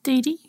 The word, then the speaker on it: Dedi: And then you have the oldest recorded magician comes from ancient Egypt papyrus, which is a Dedi. I'm not sure how you pronounce it. – Dedi.